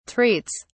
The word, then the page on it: treats: British English